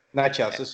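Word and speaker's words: Nečas: Martin Nechas, I can never say his name, coming through the pipeline. – Nechas is correct, yeah.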